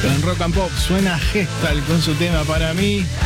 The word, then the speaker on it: Hestald: Gestalt, se escribe con H, así como suena, H-E-S-T-A-L-D, Gestalt.